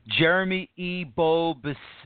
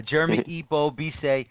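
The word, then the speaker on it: Jérémie Ebobisse: Jeremy Ebobise, that's how you pronounce it. That's what I've been watching on Twitter as well. You want to make sure you pronounce these names correctly.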